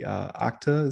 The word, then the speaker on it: Okta: Recently, they were part of the Okta, is how I pronounce it, O-K-T-A, Okta breach that put thousands of companies on alert.